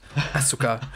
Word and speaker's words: Asuka: I'm going to try and use the Japanese pronunciations. Asuka.